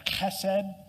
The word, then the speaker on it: chesed: The Hebrew word here that's translated into English is called hesed. It's actually pronounced like chesed, but kind of sounds gross.